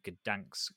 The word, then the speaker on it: Gdańsk: Their World Open qualifier, which is being held in Gdańsk in Poland.